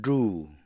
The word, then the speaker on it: drew: คําว่า drew D R E W เป็นอดีตของคําว่า draw D R A W การออกเสียงมันต่างกันดังนี้ drew draw Drew, draw.